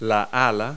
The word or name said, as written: wing